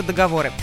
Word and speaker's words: договор: Единственный способ правильного варианта с ударением на последний слог. Договор. И никак иначе, друзья.